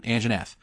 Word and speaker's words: Anjanath: Anjanath. That's a monster. That's how you say its name. – one more time for the people in the back. – Enjanath.